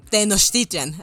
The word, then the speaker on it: Tenochtitlan: Entonces, Tenochtitlan. – Tenochtitlan, ah, así es.